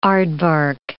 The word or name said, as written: aardvark